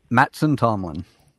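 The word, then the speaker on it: Mattson Tomlin: Wait, how do you pronounce his name? – Matson Tomlin.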